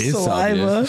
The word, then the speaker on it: Saliva: Click, click, boom's fucking salvia. Salvia. Saliva. – Saliva. – Wait, is it not salvia? – No, it's saliva. – Oh. I've been saying that wrong.